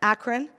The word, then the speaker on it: Akron: Akron, is that how you say it? Akron? Akron.